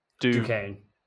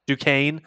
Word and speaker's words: Duquesne: How do you say the first one? – Duquesne. – Duquesne.